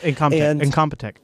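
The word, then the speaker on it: Incompetech: I think it's Incomptech, I think is how you pronounce it. – Yep, Incomptech.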